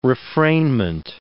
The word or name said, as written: refrainment